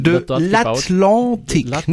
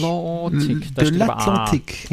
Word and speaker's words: l'Atlantique: Es wird ja auch das nächste Oasis-Class-Schiff, also die allergrößte Schiffsklasse der Welt, ähm, wird bei äh, Chantier de l'Atlantique, jetzt habe ich es richtig rausgekriegt, Chantier de l'Atlantique, es, es, es wird. Ähm, de l'Atlantique, nicht de l'Atlantique. Ja, oh. aber das oh. wird en gesprochen.